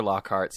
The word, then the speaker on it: Lockhart: It's B-E-Y-O-N-C-E. It sounds different than it's spelled. It's at Chester Lockhart, C-H-E-S-T-E-R-L-O-C-K-H-A-R-T. It's like Lockfart, but with an H.